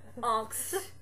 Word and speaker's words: Angst: And, if he beats Harry and Teen Angst, is that how you pronounce it? – What? He beats Harry and Teen Angst. Oh, Teen Angst. Yeah. Angst. That's your name from now on.